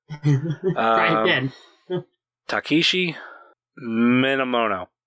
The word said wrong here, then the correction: Minamino: Minamino. It's actually Minamino. – Minamino.